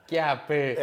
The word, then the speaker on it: Chiappe: Chiap. Sì, ma noi lo pronunciamo l'italiana. – Chiappe.